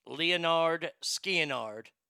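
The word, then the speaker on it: Lynyrd Skynyrd: Their first album pronounced Leonard Skianard, had the song Simple Man on there, and it also had this song.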